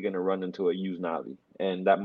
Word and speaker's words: Yusnavy: There's a lot of Cuban-Americans walking around with the name Yuznavi, and it's spelled Y-U-S-N-A-V-Y.